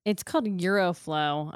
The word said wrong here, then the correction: Aeroflow: I don't even know if I'm saying it right. It's A-E-R-O Flow. Urology. – Aeroflow. So Aeroflow Urology.